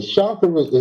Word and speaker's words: chakra: Okay, and then my last question, and again, is the chakras, is that three syllables, or is that like G, like shock G? Are you saying what chakra, in the world? – Right? Chakras, chakras. So that's two, right?